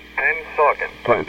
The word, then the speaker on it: Pennsauken: Pennsauken.